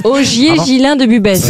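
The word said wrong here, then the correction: Busbecq: Ogier Gilin de Busbeck, pardon, j'ai écorché son nom. – Il me semblait bien aussi. – Ça, c'est chou. Ça, oui. Est-ce que vous pouvez appeler Busbeck? – Busbeck. Ogier Gislin de Busbeck. – Ah, Gislin.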